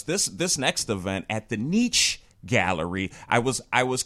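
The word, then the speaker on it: Nych: No, I looked it up on the internet, and it says pronounced Niche. So it's N-Y-C-H is the way they spell it. – Wise. – But it's pronounced Niche.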